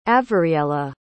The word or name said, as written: avariella